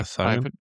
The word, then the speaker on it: Cyan: Yeah, Cyan. – I've been calling it Cyan because I think it's Cyan, like the same as the color, but I, you know...